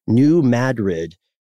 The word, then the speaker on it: New Madrid: And this is the New Madrid.